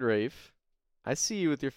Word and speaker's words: Ralph: It's already wacky enough that his name is R-A-L-P-H and it's pronounced Rafe.